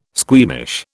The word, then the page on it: squeamish: British English